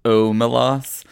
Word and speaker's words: Omelas: And to start us off, technically I think it's pronounced Omelas, according to Le Guin,